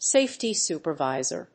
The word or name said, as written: safety supervisor